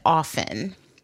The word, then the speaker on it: often: I was told that often is the correct pronunciation, that the T in that word is silent, kind of like soften.